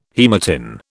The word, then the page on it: haematin: British English